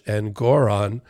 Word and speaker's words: Engoron: That's how you pronounce it, Judge Engoron, this week.